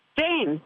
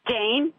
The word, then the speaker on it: Jane: Jane. – What is it? – Jane. – Spell that for me. – J-A-N-E.